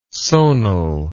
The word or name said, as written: sonal